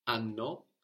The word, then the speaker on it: hanno: So we get sanno, they know, from sapere, and hanno. H-A-N-N-O, and of course the H is silent, hanno, for they have, from avere.